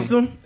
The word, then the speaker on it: Exum: Dante, I don't know how to pronounce that last name. – Dante Exum. – Exum.